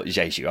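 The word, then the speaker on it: Diédhiou: Oh sorry, Diadu. – So for anyone who hasn't seen it yet, Chris Kamara, in his infinite wisdom, decided to pronounce Famara Jeju as Diadu.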